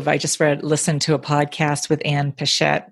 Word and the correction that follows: Patchett: Is it Pichette or? – Ann Patchett. – Patchett. That's, I knew I was saying it wrong.